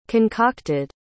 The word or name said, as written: concocted